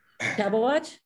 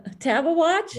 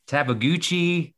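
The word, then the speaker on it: Tabeguache: From the source on naming 14ers, how do you pronounce that sub-watch range 14er that starts with a T? – Tab a watch? – Yeah, can you say it one more time? – Tab a watch? – Yeah, okay. I've heard a lot of people butcher that name. – Oh, what do they say? – Oh, Tabaguchi. What else? – Yeah, but then is it Shavano or Shavano?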